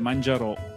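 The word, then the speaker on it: Manjaro: C'è l'accento però, è Mangiaro.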